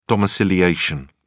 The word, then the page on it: domiciliation: British English